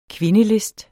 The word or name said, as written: kvindelist